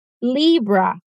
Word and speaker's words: Libra: Claro que em inglês a pronúncia vai ficar um pouquinho diferente. Libra. Libra. O símbolo de Libra é uma balança, ou seja, representam equilíbrio.